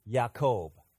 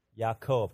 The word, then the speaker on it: Yaakov: Jacob, when you say Jacob's name in Hebrew, it's pronounced Yaakov. Okay, can you all say that? 1, 2, 3. Yaakov.